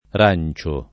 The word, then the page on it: Rancio: lingua italiana